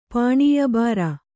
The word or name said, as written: ಪಾಣೀಯಬಾರ